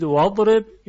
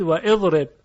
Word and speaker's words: واضرب: مثلا در اضرب یه واو سرش بگذارید و تلفظ کنید، آیا میگویید واضرب یا میگویید و اضرب؟ طبیعیه که میگوییم واضرب. بنابراین همزه در حالت وصل افتاد و ساقط شد، پس همزه وصله.